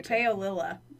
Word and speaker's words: Paolilla: Paolilla.